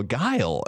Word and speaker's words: Guile: Oh, the main thing that just came to my mind, because I was just thinking about how we pronounce Guile, goalie. And then I feel like it was Mike Martin or something like that said, oh, Guile.